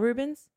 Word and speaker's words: Rubens: Um, do you know who Ruben is? I'm not saying it right, am I? – Peter Paul Rubens. – Is, is that it? That sounds right.